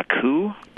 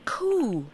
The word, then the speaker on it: coup: What four-letter word ending in U-P has a silent P? – Has a silent P? – Uh-huh. Blank, blank, U-P, and the P is silent. – A coup? – Coup. – Coup, C-O-U-P.